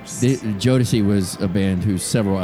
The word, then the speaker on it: Jodeci: Uh, is this pronounced Jodecki? – Jodeci.